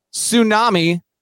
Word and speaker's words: tsunami: And uh, you can obviously see the sibilance here stumble, skid, slide, swoon, slump, spiral, and then Tsunami, the T is silent.